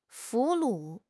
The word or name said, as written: 俘虏